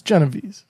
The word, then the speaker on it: Genovese: Yeah, because it's probably pronounced like Genovese. – Yes. Yes. – It's Genovese. Like, I don't, but I'm not going to get into pronouncing it like an Italian.